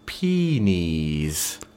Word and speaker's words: penes: The plural is, in fact, it's pronounced, it's spelled P-E-N-E-S, and you pronounce it peenies. – Peenies. – Yeah, you pronounce it peenies, so P-E-E-N-E-E-Z. Peenies.